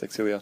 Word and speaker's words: Xillia: It's actually is Exilia. Isn't that weird? Yeah, it's pronounced Exilia.